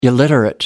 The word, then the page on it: illiterate: American English